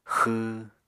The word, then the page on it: xw: Apatani